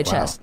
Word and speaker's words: chess: Oh, it's chest. Not I've been pronouncing it wrong. – Oh, the T is silent. – Yes. – Oh, the T is. We don't pronounce the T.